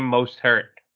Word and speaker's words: Mostert: You said it wrong, Wes. It's Raheem Mostert.